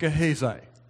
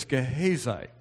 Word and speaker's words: Gehazi: Gehazi is pronounced Gehazi. And from now on, we'll do it correctly. I remembered way back from a long time ago preaching this in Ottawa. An elderly woman told me, it's Gehazi, Pastor.